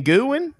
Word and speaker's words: Guin: there's a town that is spelled G-U-I-N. And people say, oh, yeah, I was driving to the stadium and I passed through Gwynn. And they're like, where the hell is Gwynn? And they're like, oh, you know, it's over there on the west side of Tuscaloosa. And you say, oh, you mean Gwynn? That's how it's pronounced.